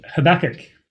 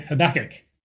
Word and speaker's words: Habakkuk: And so we're going to look at the prophet Habakkuk. Now, the word Habakkuk, or his name Habakkuk, is pronounced quite differently depending on who you ask.